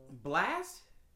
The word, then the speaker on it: Blxst: But yeah, headshots by I don't even know how to pronounce this. BLXST. – Blast. – Yeah. – Yeah, Blast. – Yeah, Blast. It was without the fucking A.